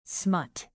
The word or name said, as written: smut